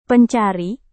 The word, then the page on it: pencari: Indonesian